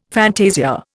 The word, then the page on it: fantasia: Indian